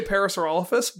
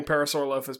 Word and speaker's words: Parasaurolophus: So I say Parasaurolophus, but I don't begrudge anyone saying Parasaurolophus, because there's a reasonable argument to be made that that's also a correct pronunciation.